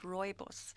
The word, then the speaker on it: rooibos: How do you pronounce it again? – It's rooibos. – Rooibos. So, which actually translates to red bush. Roy is red and boss is bush. So it's rooibos. It's red bush tea.